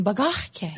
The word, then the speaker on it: bagahke: We like that one, bagahke. – Yeah. – But it's really, emphasize on that, bagah.